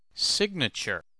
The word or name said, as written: signature